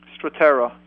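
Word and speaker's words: Strattera: Are you familiar with a medication by the name, I may be saying it wrong, Strata? – Stratera, yes.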